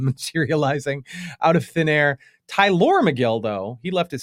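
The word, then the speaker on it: Tylor: The Mets announcers, by the way, definitely, I assumed it was just pronounced Tyler. It's T-Y-L-O-R, but the Mets announcers. It is Tyler. – It is, the way they pronounced it was definitely Tyler McGill, who started for the Mets on Sunday.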